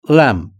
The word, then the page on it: lamp: British English